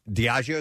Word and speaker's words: Diageo: Diageo, is that how you say it? – Oh, yeah, yeah.